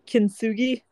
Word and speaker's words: Kintsugi: Kintsugi.